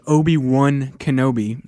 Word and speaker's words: Obi-Wan Kenobi: The character is Obi-Wan Kenobi, and it's W-A-N, O-B-I-W-A-N. So it's Hobie, W-A-N, Hobie-Wan.